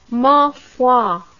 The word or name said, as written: ma foi